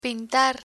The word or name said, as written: pintar